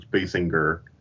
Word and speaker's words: Basinger: it's Basinger, and you're supposed to hit the G a little hard, so it's Basinger.